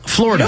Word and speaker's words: Florida: It's pronounced two syllables. – Florida. – You don't pronounce the id.